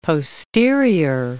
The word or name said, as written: posterior